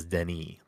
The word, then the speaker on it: Denis: It's like it's technically, I think his first name is Denis. Like you don't pronounce the S. It is Denis.